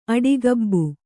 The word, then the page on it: ಆಡುಗಬ್ಬ: kannada